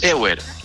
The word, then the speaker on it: Ewer: Depende. En Chile es John Ewer. – Ok. – John Ewer. pero de origen es John Ewer, pero es un apellido inglés, pero aquí es John Ewer.